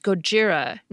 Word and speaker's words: Gojira: By the way, you might have noticed I pronounced the name of the 1954 movie as Gojira, not Godzilla. That's because Godzilla is an anglicized version of the Japanese word Gojira.